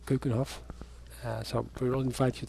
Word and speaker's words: Keukenhof: Kuchenhof, it's spelled K-E-U-K-E-N-H-O-F, Kuchenhof. – K-E-U-K-E-N? – Yeah, H-O-F. H-O-F.